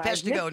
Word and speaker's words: Peshtigo: And so, you know, the ones that folks hear about in terms of the water being contaminated in French Island and Peshtigo. And forgive me, I'm from Michigan. So it's Peshtigo.